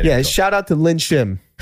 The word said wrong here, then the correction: Shin Lim: Shin Lim. – Oh, Shin Lim. That's what I said. – He said it wrong.